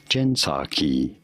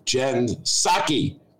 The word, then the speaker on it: Jen Psaki: Anyway, Jen Psaki. The P is silent.